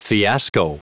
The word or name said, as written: fiasco